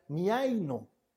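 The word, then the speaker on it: miaino (μιαίνω): Cuando el autor de Hebreos nos dice esta frase envenena a muchos, está utilizando una palabra que se pronuncia miaino. Miaino.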